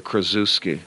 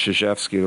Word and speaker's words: Krzyzewski: I hate the when people say Krasuski. Like it sounds like I'm a zookeeper in some crazy island. But I've heard them all, and that's why a lot of people say Coach K. But you know what? More people have said and pronounced Krzyzewski the right way than I ever imagined.